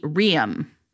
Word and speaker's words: Riam: I listened to the audiobook, so I wanted to get this pronunciation right. It's like Riam.